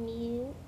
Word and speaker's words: mute: Why does how did mutt change to mute? What did we add? – Because we had the sound E at the end. – Yeah, and it changes the vowel sound.